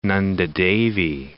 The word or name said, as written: Nanda Devi